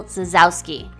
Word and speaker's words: Zasowski: On today's episode, we're talking with writer, speaker, and marriage and family therapist, Nicole Zasowski. – Woo! – Did I say it right? – Yeah.